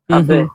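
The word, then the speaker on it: Абе (安倍): Абе, это в конце скорее русское «э» все-таки такое, знаете, абе. – Ну, конечно, интересно. – Абе будет правильное произношение, но, опять же говорю, я не хочу менять мир японистики.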